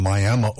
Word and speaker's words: Miami: Before Shania, we had Keith Anderson on and Pickin' Wildflowers, a young man from Miami, Oklahoma. And yes, that's how you say it. There's Miami is in Florida. Miami is in Oklahoma. Spelled the same way.